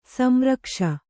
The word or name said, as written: ಸಂರಕ್ಷ